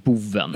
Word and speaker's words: Bowen: B-O-W-E-N. – Ja. Boven. Nej. – Men jag tror att hans artistnamn var, man skulle uttala det som Boven.